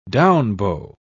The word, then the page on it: down-bow: British English